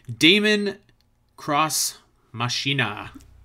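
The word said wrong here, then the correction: Daemon X Machina: It's Daemon Ex Machina. – Daemon Ex Machina.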